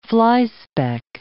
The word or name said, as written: flyspeck